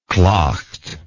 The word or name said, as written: claucht